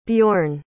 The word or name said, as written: bjorn